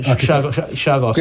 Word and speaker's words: Chagos: But the positive was the UK government has agreed to give the Chagos Archipelago, how do you pronounce it? – Yeah, Chagos.